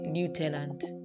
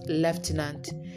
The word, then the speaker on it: lieutenant: Lastly, I hear the people say lieutenant. Yes, some people say lieutenant. But in the real sense, it's pronounced lieutenant.